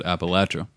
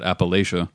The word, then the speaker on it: Appalachia: There's a whole thing essentially from western North Carolina, eastern Tennessee, all of West Virginia and Kentucky, and southern Virginia, that the natives there have always and still do pronounce it Appalachia. – Okay. – But every outsider that comes in pronounces it Appalachia.